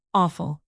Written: offal

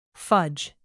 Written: fudge

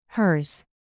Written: hers

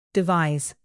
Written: devise

